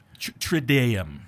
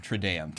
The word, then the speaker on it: Triduum: I never pronounced that correctly. – Triduum. – Triduum. There you go.